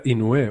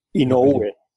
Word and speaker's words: Inoue: Se pronuncia Inoue o algo así, ¿verdad? – Inoue, ¿Y no V se pronuncia? – No V. E. Ah, y, me, he entrenado, he preguntado, es un, es un nombre japonés, no es hawaiano.